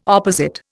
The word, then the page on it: opposite: Indian